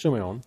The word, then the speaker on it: Simeon: It's written like Simeon, but it's actually, the pronunciation is Simeon.